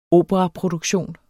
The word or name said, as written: operaproduktion